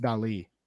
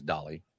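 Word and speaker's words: Dalí: The eye actually has an accent over it. So it's Dali. – Except for even he pronounced it as Dolly.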